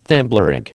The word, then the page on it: Thimblerig: American English